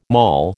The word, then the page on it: mall: American English